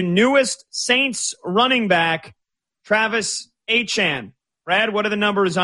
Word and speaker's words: Etienne: Again, it is pronounced Travis Etienne.